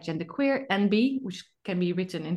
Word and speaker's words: enby: It's NB as in N and then a B, you know, non-binary. But a lot of non-binary people refer to themselves as NBs, and then it's spelled ENBY.